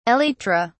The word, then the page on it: elytra: British English